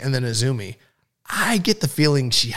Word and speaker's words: Azumi: So yeah, her future reference, it's Azumi. – Right. Whether you see it spelled out or it's spelled AZM, it's pronounced Azumi.